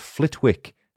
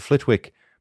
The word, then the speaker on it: Flitwick: Number one, Flitwick has a silent W in its name. Whilst writing the script for this episode, I was convinced that the town's name was pronounced Flitwick, as in Professor Flitwick, but alas, I was wrong. It's pronounced Flitwick, despite being spelt F-L-I-T-W-I-C-K.